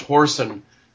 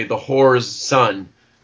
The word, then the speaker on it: whoreson: It's funny in the beginning, the first scene, he, we have the word whoreson, which is usually pronounced horsen but in our production we decided to say the whore's son hmm.